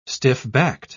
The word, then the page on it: stiff-backed: British English